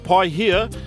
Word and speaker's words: Paihia: The place, pai here, it's spelled P-A-I-H-I-A.